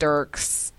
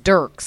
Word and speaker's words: Dierks: There's a country singer, but he spells it differently. It's like, I think it's pronounced Dirks. It's Dirks. – Dirks Bentley. – Yes. D-I-E-R-K-S.